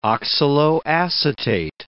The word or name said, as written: oxaloacetate